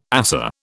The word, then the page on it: ASA: British English